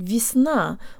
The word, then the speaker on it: весна: guys, today you'll learn how to pronounce the word весна, or spring. The first y sounds like an e, and the v has a hint of a y sound in it.